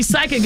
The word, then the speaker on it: psychic: So you're right, because when we see psychic, it's spelled P-S-Y, right? – I couldn't tell you. – Yes, yeah. This, yep. – So the P is silent.